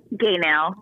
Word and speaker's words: Gaynell: Gaynell, that was close enough.